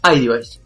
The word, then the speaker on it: iDevice: Bueno, es una mesa para tus e-device. ¿Lo dije bien en inglés? ¿E-device? – iDevice. – iDevice. Perdón, tenés razón.